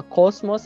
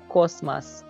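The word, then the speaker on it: cosmas (космос): Então é a mesma palavra, só que quem já estuda russo deve saber que uh, o átona em, em, nas palavras russas não é pronunciado como O, é pronunciado como A. Então a gente não fala cosmos, a gente fala cosmas.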